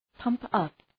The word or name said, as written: pump up